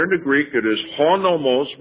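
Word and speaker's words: honomos (ὁ νόμος): The English word, the law, with the definite article, comes from the Greek, honomos. That's the way it's pronounced. There's a h before the O.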